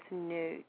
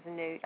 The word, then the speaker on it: Nut: And I do believe it's Nut. The pronunciation is Nut.